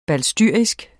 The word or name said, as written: balstyrisk